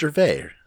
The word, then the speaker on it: Gervais: I like that you're pronouncing the S. – How do you say his name? – Just Ricky Gervais. – I don't fucking know. – God, uncultured swine over here. – I think I might be wrong on this. – No, it's just Gervais.